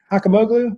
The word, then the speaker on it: Hakimoglu: Did I pronounce that right, Zee Hakimoglu? – That's right. The G is silent. It's really Hakimoglu, but that's just fine.